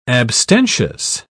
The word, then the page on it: abstentious: British English